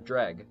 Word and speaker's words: Jreg: Jreg, Dreg, however you pronounce it. – Dreg. Yeah. – Dreg.